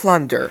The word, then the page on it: plunder: American English